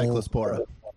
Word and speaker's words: Cyclospora: I mean, honestly, like literally every day now, the Cyclosopolis, I can't pronounce it, is still Cyclospora.